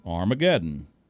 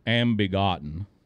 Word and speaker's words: Armageddon: And a lot of the old-timers mispronounced, to this day, the word Armageddon. Would you like to take a guess how they pronounced it? Hank Williams pronounced it, as does Larry Sparks, among others, Ambegotten.